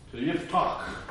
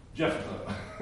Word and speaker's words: Yiftach: Okay, so now we move on to Yiftach, or if you want to just pronounce it, Jephthah, which is the way I originally pronounced it until I looked at the actual Hebrew.